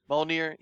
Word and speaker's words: Mjolnir: How do you pronounce Mjolnir. – It? Mjolnir.